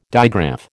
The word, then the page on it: digraph: American English